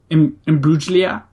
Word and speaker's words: Imbruglia: I think it's a soft G. – Imbruglia? – I think that's closer.